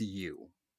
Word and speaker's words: Yuh: I want to say, is that Y-U-H? I want to say it's you. That's how you pronounce it.